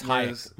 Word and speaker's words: Hájek: I mean, we talk more about Libor Hájek, and you don't even know how to say his name. – It's Hájek, Michael.